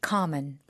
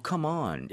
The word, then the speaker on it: common: common. – one more time. – common. okay, common. – common. Right. 아니 왜냐면 강세 잘못 주시면 come on 이렇게 되니까 Yeah.